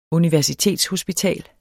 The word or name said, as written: universitetshospital